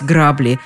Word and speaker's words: грабли: Ситуацию надо срочно исправлять, то есть покупать грабли. Без грабель или без граблей не обойтись. Ну и главное, что нужно запомнить, ударение всегда на «а»